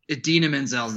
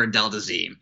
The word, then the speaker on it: Idina Menzel: He badly mispronounced Idina Menzel's name, called her Adele Dazeem.